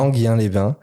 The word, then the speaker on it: Enghien-les-Bains: où il présentait le film à Enghien-les-Bains, je ne sais pas comment on prononce, mais Enghien, je crois. – Enghien-les-Bains, ouais.